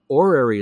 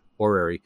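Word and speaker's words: horary: There's another branch of astrology called orary astrology, which is spelled with an H, orary.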